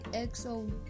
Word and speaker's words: EXO: EXO.